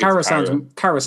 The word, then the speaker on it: Khaira: And then they signed Jujhar Khaira. I'm probably saying that name wrong. I have no idea. – Khaira? I think Khaira sounds right.